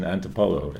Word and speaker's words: Antipolo: And it's in Antipolo, am I saying that right? – Yeah, city.